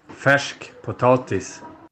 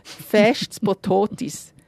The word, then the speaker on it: färsk potatis: Darum habe ich auch hier nochmal meinen schwedischen Nachbar um die richtige Aussprache gebeten. – Man sagt «Färsk Potatis». – Zum Nachsägen wirklich ein bisschen schwierig. Ich probiere es einmal. «Färsk Potatis».